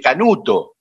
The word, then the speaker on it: Canute: pero se pronunciará Canute así. – Eh, sí, en castellano es más, es Canuto.